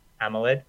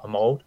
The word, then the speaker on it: AMOLED: Yeah, besides Croker's bad pronunciation of Amoled, not Amold, but it was a great list.